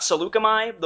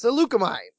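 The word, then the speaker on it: Saleucami: The other one was the Battle of, and I'm going to say it wrong, Seleucicrum. – You're talking about Seleucami, the one that's... – Yes! Yes, Seleucami.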